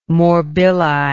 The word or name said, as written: morbilli